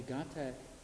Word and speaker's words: gatha: A gatha is, so this word is spelled G-A-T-H-A, and this T-H sound that's transliterated from Sanskrit is actually a aspirated glottal stop. It's not actually a th sound, so it's just pronounced gatha.